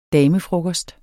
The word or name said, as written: damefrokost